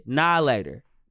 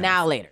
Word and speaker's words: Now and Later: Is it now and later or is it nah later? Because nah later is how I pronounce it. – Now later. – I thought it was now later. – I thought they were now or later. – They're now and later.